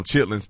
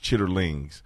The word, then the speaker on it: chitterlings: Some people may not know what chitlins are because we call them chitlins. Technically, they're pronounced chitterlings, but chitlins are pig intestines.